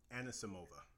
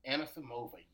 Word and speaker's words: Anisimova: Anisimova. – Anisimova, yes.